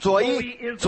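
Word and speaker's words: 左 一 (ζωή): 看一下这个字的希腊文，它读成左一，左一就是圣灵。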